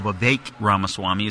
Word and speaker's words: Vivek Ramaswamy: But Vivek Ramaswamy, Sean McConnell has checked me on that. So Vivek Ramaswamy is how you pronounce his name.